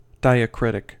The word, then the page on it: diacritic: American English